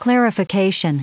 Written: clarification

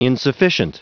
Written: insufficient

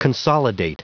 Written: consolidate

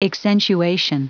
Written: accentuation

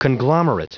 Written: conglomerate

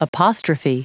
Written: apostrophe

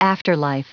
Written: afterlife

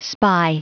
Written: spy

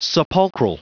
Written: sepulchral

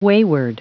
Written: wayward

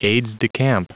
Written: aides-de-camp

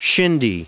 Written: shindy

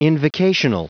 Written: invocational

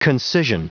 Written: concision

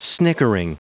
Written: snickering

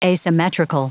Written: asymmetrical

